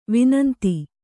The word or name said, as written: ವಿನಂತಿ